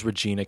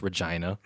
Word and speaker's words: Regina: I thought it was Regina, Canada. It's actually pronounced Regina.